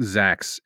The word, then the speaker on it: Zxaxz: We learn the lore of a very powerful being called, are we calling him Zax? – Yeah, like I think that's... – I've been struggling. Guys, yeah, it's spelled Z-X-A-X-Z. – Yeah, so it's a palindrome with Z, X, and A, and I think Zax is what we're going to go with.